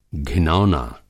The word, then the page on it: घिनौना: Hindi